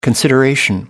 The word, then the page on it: consideration: American English